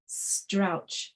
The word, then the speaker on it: Strauch: Out of the Silence After the Crash by Eduardo Strauch, I think is how you say it, S-T-R-A-U-C-H.